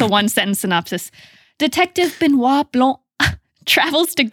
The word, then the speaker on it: Benoit Blanc: Slim, let's solve a freaking mystery with our best friend, Benoit Blanc.